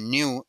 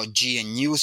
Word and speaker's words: GNU: Eh, si usas un Android, estás usando un, eh, no exactamente lo que se conoce como GNU Linux, se pronuncia new en inglés, no GNU, sino new, esto me lo dijo Stallman en un reportaje.